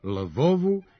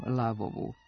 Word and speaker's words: Lavovu: Ukrajinci su pobedili sa 5-0, a nama je ostala dilema da li se meč igrao u Lavovu, Lvovu ili Ljvovu. – Fudbalska reprezentacija Srbije igrala je u ukrajinskom gradu Lavovu. Pravopisna preporuka je da bi izgovor ukrajinskih imena trebalo zadržati u onom obliku u kojem su ona i dosad ulazila u našu literaturu i kartografiju, na primjer Kijev, Harkov, Poltava, Dnjestar, Dnjepar, Odesa, Zaporožje pa i Lavov.